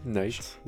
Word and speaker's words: Knight: comme, la nuit, Knight. – ah non, ah non c'est pas, la nuit, c'est le chevalier. Oui, mais, mais tu ne prononces pas le que.